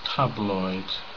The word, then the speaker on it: tabloid: So, where's the stress in this word? Which syllable is stressed? Stress syllable. Yeah. Tabloid.